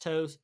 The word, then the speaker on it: TOSE: I think it's pronounced Toes, T-O-S-E,